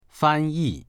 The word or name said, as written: fan1yi4